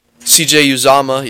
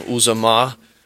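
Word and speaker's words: Uzomah: CJ Uzama, yes, I finally pronounced it right. I was saying Uzama is playing, which is great for the Bengals because then you add that extra weapon there.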